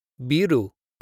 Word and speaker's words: biru: For example, biru kudasai. Biru kudasai. Ru is a little bit strange because it's pronounced halfway between an L and an R, but you could just say it as an R.